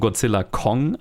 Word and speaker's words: Godzilla x Kong: Wir besprechen heute Godzilla X Kong, wobei ich von im Interview mit Adam Wingard gehört habe, dass man das X nicht aussprechen soll. Das ist wie ein Featuring. Also ist es Godzilla Featuring Kong oder einfach nur Godzilla Kong eigentlich? – Godzilla Kong.